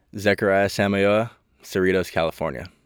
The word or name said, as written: Zech Samayoa